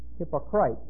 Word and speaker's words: hypocrite: Hypocrite, if you pronounce it properly in English.